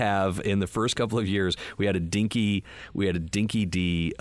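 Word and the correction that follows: Dinky Di: dog food eating contest. – Hopefully it wasn't Chinese dog food. – No, sorry, Dinky Dai. Oh, I can't believe I pronounced it wrong.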